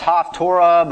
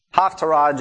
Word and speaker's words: Haftarah: Sometimes it's pronounced Haftorah, but basically it's Haftarah just means parallel to.